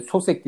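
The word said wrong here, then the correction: Souček: diye okunuyordu? Suçek. Suçek. Suçek.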